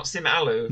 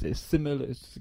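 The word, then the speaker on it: Sim Aloo: I mean, it's not Simalu. – No, it's Simalu. It's two words, but you've got to say it like it's one word.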